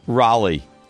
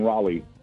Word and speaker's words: Raleigh: Raleigh. – Okay. Mike Maniscalco? – Here in Raleigh. – Kenny Albert? – In Raleigh. – See? – North Carolina. – It's not Raleigh.